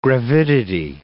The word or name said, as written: gravidity